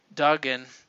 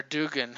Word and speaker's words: Duggan: And then we get into another Marvel 5 out of 5, which is 1872 by Jerry Duggan, or Dugan, how you want to pronounce it.